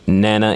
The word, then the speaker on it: Nana: And you can also follow me on Facebook, Nana, N-A-N-A. Last name is Ajiman, A-G-Y-E-M-A-N.